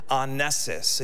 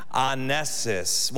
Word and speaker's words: ainesis: Well, in, in the scriptures, again, specifically Hebrews chapter 13, the English word for praise comes from the Greek word ainesis. And you can see this up there. Can you say that with me? Ainesis. One more time. Ainesis.